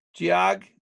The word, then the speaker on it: Geog: Geog? Like geography is actually how you say it.